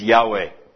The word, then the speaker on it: Yahweh: The Lord, those two words, are one word in the Hebrew, which is simply YHWH, which may be pronounced as Yahweh.